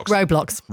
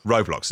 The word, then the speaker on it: Roblox: Still to come, if your kids play now, I don't know how you pronounce it. Is it Roblox or Roblox? – Roblox. – Roblox, there we go.